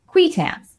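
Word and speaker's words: qui tams: and those lawsuits are called QUITAMs, which is the proper pronunciation. That is not how I pronounce it, to my great shame. I cannot not say QUITAM.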